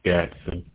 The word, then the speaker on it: Gadson: Gatson. – Can you spell that? – G-A-D-S-O-N.